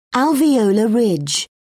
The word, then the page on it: alveolar ridge: British English